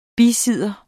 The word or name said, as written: bisidder